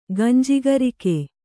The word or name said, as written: ಗಂಜಿಗರಿಕೆ